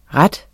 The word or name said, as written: rette